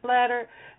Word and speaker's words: Blatter: Yes, his name is Blatter. I said that right.